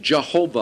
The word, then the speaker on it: Jehovah: Now, I think it's very interesting that Arnold Fruchtenbaum calls the Lord, YHWH, Jehovah, all the way through. And he considers that the proper pronunciation from what I'm able to determine.